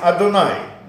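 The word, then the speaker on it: Адунай (אדני): А мы его произносим не так, как оно читается, как произносим «Адунай», да, тот, кто знает.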